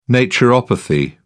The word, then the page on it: naturopathy: British English